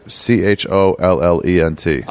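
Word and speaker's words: cholent: You just like chollent. – Oh, okay. I'm not going to get anywhere close on that. – You barely pronounce the L.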